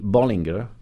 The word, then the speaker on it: Bollinger: How do you pronounce the name of the house? Or do you just cheat like so many fans do and call it Bali? – Yeah, it's, I mean, it should be Bollinger.